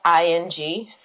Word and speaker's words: Ng: I'm not sure I'm pronouncing the last name right. NG. – Her Instagram handle or something is pronounced ING.